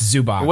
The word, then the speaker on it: Zubac: So, sorry, I know I pronounced his name wrong. It's Zubac.